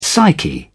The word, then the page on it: psyche: British English